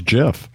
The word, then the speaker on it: GIF: Yes, I've been told by the by the uh, straight from the creator's mouth that it is pronounced GIF. So, like the peanut yes, butter? – Exactly, just with a different letter.